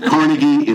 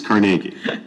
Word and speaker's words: Carnegie: So instead of Carnegie, it's Carnegie.